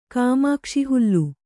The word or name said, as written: ಕಾಮಾಕ್ಷಿಹುಲ್ಲು